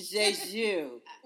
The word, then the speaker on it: Jeju: Juju. – No, it's not juju. That's from Star Wars. That's the Southern version.